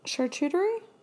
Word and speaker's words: charcuterie: charcuterie board and how do you say that? Charcuterie. Charcuterie.